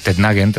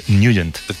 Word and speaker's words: Nugent: Hombre, siempre lo hemos llamado en el barrio Tednagen o Tednagen. – Claro, yo era de Tednagen. – Pero la pronunciación correcta es Nugent.